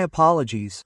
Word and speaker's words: apologies: And notice the ES. Let's pronounce it like a Z, okay? My apologies. Z. Ending with a Z there.